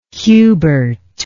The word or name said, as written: hubert